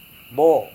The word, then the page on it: bo: Serewen